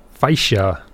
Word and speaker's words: Faixa: It's called Dark Forces, and that is Faixa number one. It's spelled F-A-I-X-A, Faixa.